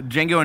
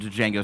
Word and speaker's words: Django: It's like the same scene where like they're at the bar and Django says like spells out his name, but it's like the J is silent.